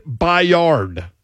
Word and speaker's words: Byard: Some pronounce it Byard. – Yes. – Kevin Byard. B-Y-A-R-D.